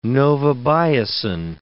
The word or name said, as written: novobiocin